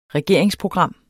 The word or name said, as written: regeringsprogram